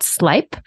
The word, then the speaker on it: Sleip: I know that now you're the global customer success manager for a really exciting new technology called Slipe. So glad the last time we chatted that you actually taught me how to pronounce it. S-L-E-I-P for anybody listening.